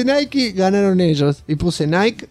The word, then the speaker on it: Nike: Francisco Jamier Zamudio González dice: muy bueno el programa, me hicieron reír mucho, excelente, pero Saki, déjalo a Shingo que pronuncie Nike. – que está correcto. Vamos, el chingo anglosajón tosquero todavía. Y por si alguien quiere decir que está mal pronunciado así, dejo esto. Y dejo un link de YouTube, de la publicidad de Nike, de las zapatillas de Volver al Futuro. Y sí, ahí dice Nike.